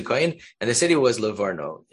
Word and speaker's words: Livorno: Livorno. That's how you pronounce it.